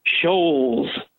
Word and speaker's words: Scholz: Hey, I want to congratulate you on pronouncing it correctly, and I still get it wrong. Scholz, not Scholz.